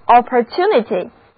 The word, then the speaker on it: opportunity: 第一个字母 O 读作 O，而字母 U 要读它的本音 U，重音在字母 T 所在的音节，一起来读两遍吧， opportunity。